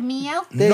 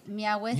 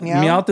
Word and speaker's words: Meowth: Meowith. – You want to be different. – No. In my brain, that's how you say it. – M-E-O and then W-T-H. – Meowith.